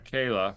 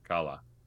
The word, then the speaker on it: Kala: Kala.